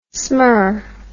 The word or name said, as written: smur